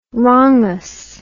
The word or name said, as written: wrongous